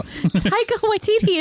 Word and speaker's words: Taika Waititi: is Taekwondo Waititi. – No. – I can't ever pronounce his name. – Taika Waititi. It's not that hard.